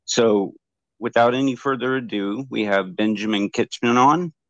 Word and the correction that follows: Kintish: It's Kintish. – Kintish. – It rhymes with mintish.